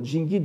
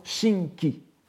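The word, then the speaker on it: Jingi (神祇): Les plus pointilleux d'entre vous auront remarqué comme dans le cas de Shoka Jōge tout à l'heure, n'est-ce pas, qu'il s'agissait, dans le cas de Jingi, d'une prononciation dite Go-on. La prononciation « Ka-on », la prononciation donc euh, modelée euh, sur, celle des, Tang, étant Shinki, ou à la rigueur Shingi.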